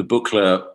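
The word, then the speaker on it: Buchla: the Buchler, I mean, I'm sorry about my pronunciation. – Oh, you said it right.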